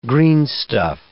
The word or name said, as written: greenstuff